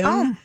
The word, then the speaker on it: Homme: How do you say it? – Homme. – Homme. It's Homme. You're right.